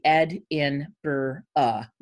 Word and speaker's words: Edinburgh: So write it out phonetically, Ed-in-bur-uh, you know, as opposed to Edinburgh.